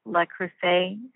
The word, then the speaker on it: Le Creuset: I forget how, I'm not sure how you, here I go again with my not being able to pronounce things, but the Le Creuset, is that how you say it? – Yes, that's right.